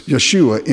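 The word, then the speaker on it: Yeshua (ישוע): Well, Yeshua is Joshua, but you don't pronounce the J in Hebrew. So Jesus' name in Hebrew is Yeshua or Joshua or Yahshua, if you would prefer. In Hebrew, it's Yeshua.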